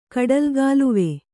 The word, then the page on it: ಕಡಲುಗಾಲುವೆ: kaḍalugāluve